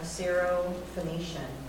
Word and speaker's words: Syrophoenician: The woman was a Greek, a Syrophoenician by birth, and she kept asking him to cast the demon out of her daughter.